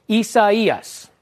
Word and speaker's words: Isaias: This one's got Spanish and Portuguese origins, Isaias. I had some people that were messaging me last night saying, you're not saying it right. I am saying it right, all right? You're saying it wrong if you're not saying it like this.